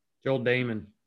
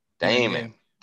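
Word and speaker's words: Dahmen: Is it Dot? I took him out of lineup. Damon? – Joel Damon. – Damon. Damon. Yep.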